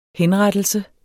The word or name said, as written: henrettelse